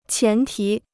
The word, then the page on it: 前提: qián tí